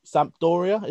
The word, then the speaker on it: Sampdoria: And was at Sampdoria.